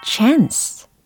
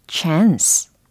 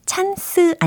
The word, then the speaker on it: chance: chance, chance. 이 발음이었죠. 가능성 혹은 기회라는 의미로 많이 쓰입니다. chance, chance. 찬스 아니고 찬스 아닌 chance 였습니다.